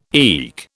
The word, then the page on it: eke: British English